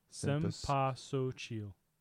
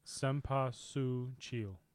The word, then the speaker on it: Cempasúchil: The flower. Sem, S-E-H-M. Pa, P-A-H. Su, S-O-O. Chil. C-H-E-E-L. Cempasúchil.